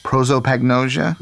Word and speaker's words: prosopagnosia: Am I saying this right? Prosopagnosia? That's right, she said.